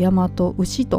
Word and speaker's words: 大和牛: この辺ね読み方ややこしいんですが、えー、大和牛と読みますということですね。